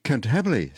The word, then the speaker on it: Cantabile: Cantabile.